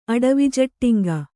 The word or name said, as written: ಅಡವಿಜಟ್ಟಿಂಗ